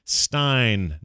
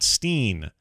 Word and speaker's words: Wolfenstein: It's true that in German, it would be pronounced with a long I sound like Stein, not Steen.